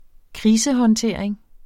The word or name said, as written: krisehåndtering